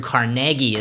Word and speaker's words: Carnegie: And well, and political beliefs, you mentioned, I watched a great documentary on Andrew Carnegie is how it's pronounced.